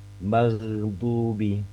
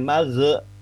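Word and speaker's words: maghdubi (مغضوب): Ketika sukun sekalipun, misalkan kalau di Al-Fatihah misalkan ghairil maghdubi maghdubi, bukan magh. Kalau magh itu kita seperti menciptakan sifat qalqalah yang seharusnya tidak ada ya, huruf ghain situ ghairil magh misalkan gitu ya, itu kurang tepat.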